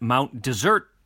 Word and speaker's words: Mount Desert: Locals pronounce it Mount Desert as if it were still a French word, which it is not, so I don't.